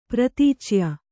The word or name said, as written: ಪ್ರತೀಚ್ಯ